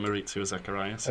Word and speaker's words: Maurizio Zacharias: So that is the writer, and I'm going to butcher this name, Maurizio Zacharias. – Yeah, I would say Maurizio Zacharias. – Okay, yeah. – Very good. They co-write, I think, the films that are considered the more successful are the ones that Maurizio and Ira have worked together.